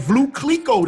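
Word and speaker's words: Veuve Clicquot: And that Vlue Clico. Did I say it right this time? – Yes, sir.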